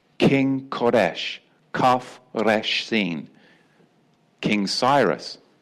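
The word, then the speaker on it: Koresh (כורש): Remember King Koresh, Cyrus, transliterated as Cyrus, but in the Hebrew, Koresh. Remember King Koresh, spelt with a kaf, resh, sheen.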